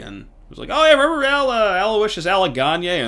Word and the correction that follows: Anagonye: and gave me a weird look, like, it's Anagonye.